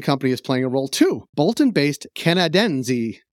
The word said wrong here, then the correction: Canadensys: No, that can't be right. Canad- Canadensys. Am I pronouncing that right or wrong, Brett? – Yeah, that sounds about right. It's definitely not Italian. So I think the second time was better.